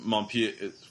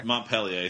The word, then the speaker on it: Montpellier: Stamboli, we got him from Montpellier. Montpellier, thank you.